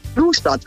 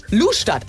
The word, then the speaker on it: Lustadt: Heißt das Lustadt oder Lustadt? Hilf uns kurz. – Lustadt. – Lustadt. Ah ja, habe ich es falsch ausgesprochen.